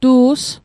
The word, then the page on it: tu's: Mi'gmaq